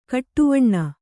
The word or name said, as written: ಕಡುವೇನೆ